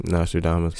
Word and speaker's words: Nostradamus: Nostradamus.